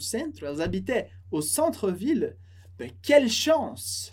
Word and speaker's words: habitaient: Elas habitaient com ENT no final, que a gente não pronuncia. E a liaison, elles habitaient.